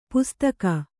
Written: ಪೊಸ್ತಕ

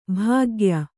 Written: ಭಾಗ್ಯ

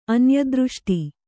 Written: ಅನ್ಯದೃಷ್ಟಿ

